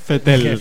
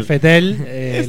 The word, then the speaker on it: Vettel: No hincha, pero lo prefiero antes que Vettel, ¿cómo se pronuncia? – Vettel. – Vettel, lo prefiero.